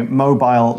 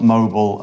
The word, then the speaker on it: mobile: Yeah, and I should say mobile, mobile are both okay pronunciations. – Oh, yeah, yeah, yeah. – British English is mobile. That's just the difference.